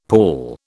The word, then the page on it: pawl: British English